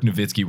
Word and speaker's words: Nowitzki: Unless it's like Dirk Nowitzki where it's spelled with a W and you say with a V.